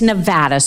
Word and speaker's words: Nevada: Nevada. You know, that's how most people back east and around the world say it, right? Nevada. – It's Nevada, sir. Please say it correctly.